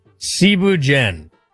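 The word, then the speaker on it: thieboudienne: All right, first off, Cebu Jen. It's spelled like Thibu Dien.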